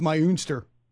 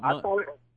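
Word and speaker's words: Muenster: It's the way the Texas pronunciation guide says, it's Meinster. – Oh, okay. – It's not Munster.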